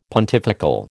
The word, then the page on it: pontifical: American English